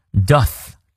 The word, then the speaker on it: doth: Doth，咬舌。